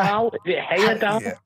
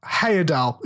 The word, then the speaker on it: Heyerdahl: I'm never going to pronounce that name. I can never pronounce his name. – Christopher Heyerdahl? – Heyerdahl. Yeah, we'll go for Heyerdahl.